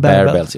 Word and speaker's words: Barebells: Visst heter de, visst du säger man Barebells? – Ja, det lär man ju säga.